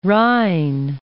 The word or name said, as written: Rhine